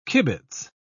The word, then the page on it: kibitz: British English